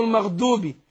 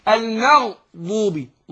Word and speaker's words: المغضوب: لان بعض الناس برضه المغضوبي المغضوبي دوبي، لا المغضوبي ضو ضو ضو،